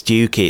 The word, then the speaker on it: Stiffkey: Stiffkey. How do you pronounce that? – Oh, is it like Stooky or something like that? – Yes. Well, this is the thing. It's like everyone says, it's like, oh, yes, no, it's Stooky, it's Stooky.